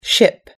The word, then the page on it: ship: American English